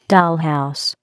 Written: dollhouse

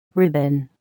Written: ribbon

